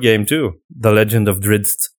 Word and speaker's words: Drizzt: It's Drizzt.